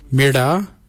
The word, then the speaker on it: mirda: Mira is red. Mira is spelled M-I-R-D-A.